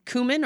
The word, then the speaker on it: cumin: Cumin or cumin, depending on how you say it.